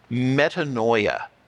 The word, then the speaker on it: metanoia: Metanoia, it's pronounced.